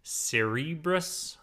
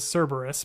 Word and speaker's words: Cerberus: I think they said, I know it as Cerberus, but I think they even said it like on the show, like a Cerberus or something like that. So I think you're okay with how you pronounced it earlier.